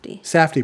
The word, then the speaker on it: Safdie: But yeah, the direction by the, how do you pronounce their name? Sadiq Brothers? – Safdie Brothers, thank you.